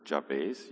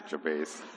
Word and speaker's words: Jabez: So Jabez was, and I pronounce it Jabez. You can pronounce it whichever way you like. We had a debate at Life Group how you pronounce it, but I'm standing in the front, so I say Jabez.